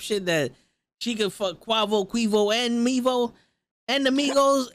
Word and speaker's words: Quavo: That's how you pronounce his name. I never knew it was Quavo.